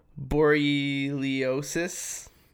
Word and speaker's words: borreliosis: it's also known as Lyme borreliosis. – That's it. – That's pronounced correctly.